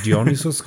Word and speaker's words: Dionysus: Auf jeden Fall für Songs, äh, für Fans der ersten Stunde haben sie auch noch einen kleinen Schmankel rausgehaut und zwar der Song Dionysus, oder wie man um das aussprechen kann. – Dionysus? Das ist halt Dionysus, genau.